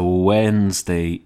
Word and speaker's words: Wednesday: Some people pronounce the D in Wednesday, don't they? To say Wednesday. Or when they're writing shorthand, they say weds. I don't know what I would say. Weds? Or I think I'd say wed, W-E-D, as in mon, Monday, chew, Tuesday, wed, Wednesday. But weds, I don't know, I have an issue with those people. And especially the people who pronounce the D in Wednesday. Wednesday is not correct.